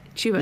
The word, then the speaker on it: Chuba: I would rather have like Chuba. Is that how you say his name? – Chuba.